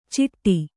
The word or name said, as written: ಚಿಟ್ಟಿ